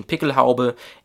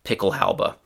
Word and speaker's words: Pickelhaube: So actually, that's the German pronunciation, Pickelhaube, and in English, the pronunciation is very similar, Pickelhaube.